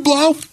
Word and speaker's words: Blough: So why not just take this guy you've got one game sample size on and say, you know what, David Blough rhymes with cow?